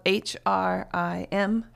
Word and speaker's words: Hrim: So you have Om, which is the primal sound of the universe, the sound from which all other sound comes out of, and then Hrim, which is spelled H R I M, Hrim,